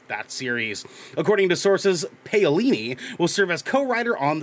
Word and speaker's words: Paolini: The series would be based on the Christopher Paolini, I think is how you pronounce Paolini.